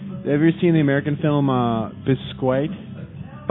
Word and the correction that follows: Basquiat: Basquiat? – Basquiat.